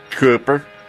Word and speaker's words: Kuper: 59-year-old John Cooper, or Cooper, how would you say this, any? K-U-P-E-R. – Cooper. – Cooper? – Yeah, like the Manning boy.